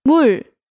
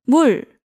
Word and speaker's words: 물: 물, 물. – So water is? – 물.